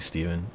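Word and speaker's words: Stephan: My name is Steven, spelled with a PH and an A, so you'd think it was Stefan, but it's actually Steven.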